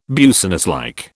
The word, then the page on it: businesslike: British English